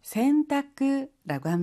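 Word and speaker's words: 洗濯: 우리는 세탁이라고 하는데요. 일본어로는 센타쿠라고 합니다. 씻을 선이라고 하는 한자가 있어요. 근데 우리는 세라는 한자음을 취하고요. 일본어에서는 선이라고 하는 한자음을 취해요. 그래서 세탁을 센타쿠라고 발음한답니다.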